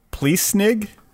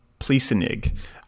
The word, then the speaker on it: Pliessnig: Uh, I guess you would say his name Pliessnig uh, P L I E S S N I G.